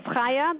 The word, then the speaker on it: Chaya: Sure, my name is Chaya. That's spelled C-H-A-Y-A.